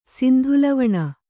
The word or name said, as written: ಸಿಂಧುಲವಣ